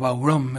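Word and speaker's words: bodhrán: Rapidito se iban, típico sonido de los Chieftains, ese bodhram, esa percusión que parece una palangana, que suena bastante horrible. Claro, fue evolucionando mucho el bodhram. En realidad se pronuncia algo así como baurom, es una palabra en gaélico, que es una especie de pandereta gigante que se usa, es una percusión que se usa para la música irlandesa.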